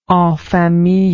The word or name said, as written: en famille